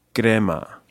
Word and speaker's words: Crema: And for those who don't know, this film is located in Italy, in Crema. Am I pronouncing that right, yeah.